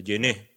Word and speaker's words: Jeunet: Eh, o Junet, eh, se pronuncia, Genet, Jean-Pierre Genet. Sí, sí, Jean-Pierre Ya lo has dicho bien.